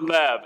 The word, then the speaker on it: Lev: Who here knows the Hebrew word Lev? L-E-V, or sometimes pronounced Lev?